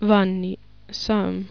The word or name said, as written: Vanni Sam